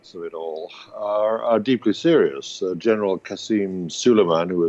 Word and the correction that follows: Soleimani: has taken Soleimani, I should say, there's a long E at the end of his name,